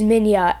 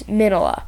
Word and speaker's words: Minilla: Uh, Japanese is Minya, and then American is Minola.